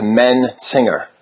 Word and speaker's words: Mensinger: Sometimes people say Messinger, so I appreciate the correct pronunciation. – Yeah, well, it's cool because like when you type it into like a phone, it separates it into Mensinger.